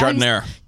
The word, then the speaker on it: giardiniera: Ginadiria? – Is that giardiniera. – Giardiniera. Thank you. Served on a warm sub roll with au jus for dipping. – What is giardiniera? – It's like sauce.